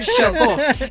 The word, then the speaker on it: Chocó: El Chocó, ¿lo dije bien? – Sí.